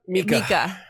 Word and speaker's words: Micah: Wasn't the husband or boyfriend's name Micah? – Mika. Yeah. – Mika. – It was Mika, Micah. Tomato, tomato. – It was M-I-C-A-H the same way I spell it, but they were pronouncing it Mika.